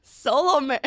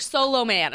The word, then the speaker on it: Solomon: Say S-O-L-O-M-A-N. I cannot get over this. – Solomon. – No, we're eating lunch last week, and she's like, solo man.